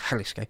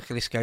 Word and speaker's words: Jalisco: Obviously, it's written Jalisco, but I think it's pronounced Jalisco.